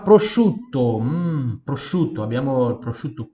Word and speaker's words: prosciutto: c SCI, U-T-T-O, temos uma dópia também aqui, dópia consonante, prosciutto, prosciutto, prosciutto. Veja que esse I, esse U, eles, eles se, se fundem né, na pronúncia. Vou falar prosciutto, não, prosciutto, prosciutto. O I e o U eles se fundem na pronúncia.